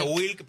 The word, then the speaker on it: Wlk: Y se pronuncia Wilk, pero es WLK.